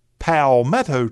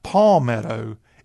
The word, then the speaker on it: palmetto: You don't have the official South Carolina palmetto tree. And first of all, if you're a South Carolinian or one at heart, you got to know how to pronounce it. It's not palmetto. It's palmetto.